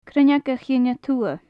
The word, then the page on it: Cruinneag a chinn a Tuath: gaelic